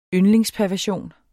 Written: yndlingsperversion

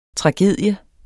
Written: TRAGEDIES

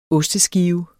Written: osteskive

